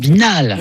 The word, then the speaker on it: Nasbinals: Vous avez plein de S qu'on ne prononce pas, en fait. – Nasbinals.